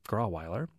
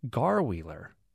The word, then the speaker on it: Grauweiler: Catherine says, while the rest of the world pronounces the street name Grauweiler as Grauweiler, those of us who are longtime Irving residents use the preferred pronunciation of Garwheeler. You can always tell how long someone has lived in Irving by the way they pronounce that street's name, and it's spelled Grauweiler.